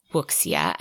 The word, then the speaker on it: wuxia: which is W-U-X-I-A. And actually, after going back through some YouTube videos, I found out we were pronouncing it Wuxia, and that is very wrong. So a more correct pronunciation of that would be Wuxia.